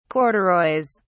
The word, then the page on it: corduroys: British English